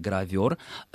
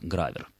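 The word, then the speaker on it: гравёр: Правильное литературное ударение «гравер», хотя в речи профессиональной это слово звучит с ударением на первый слог «гравер».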